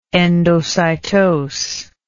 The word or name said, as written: endocytose